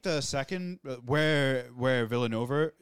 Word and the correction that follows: Villeneuve: Is that his, how you Villeneuve. – Say his name? Villeneuve. Sorry, Villanova. – Villeneuve.